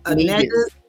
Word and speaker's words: Negus: Not, and it's pronounced Nigus. – You know it's pronounced Negus.